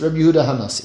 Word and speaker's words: Yehuda HaNasi: Whose son was Shimon, whose son is Reb Yehuda HaNasi.